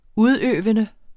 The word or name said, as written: udøvende